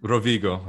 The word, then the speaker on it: Rovigo: I've never been to Rivolio, or how do you say it? – Rovigo, Rovigo.